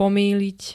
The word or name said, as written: pomýliť